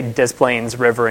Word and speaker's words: Des Plaines: no, it's not like Des Plaines, it's Des Plaines River. And so if you hear me say Des Plaines River and you think, oh my God, that guy from Ontario, terrible French accent, that is how they pronounce it there.